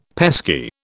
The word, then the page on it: pesky: British English